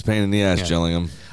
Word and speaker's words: Gillingham: Beat your bitter rivals, Gillingham, spelled Gillingham, call themselves the Gills, even though the town is pronounced Jill.